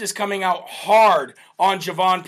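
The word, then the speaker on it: Jovan: It's not Hovan Pulitzer. It's Javon Pulitzer.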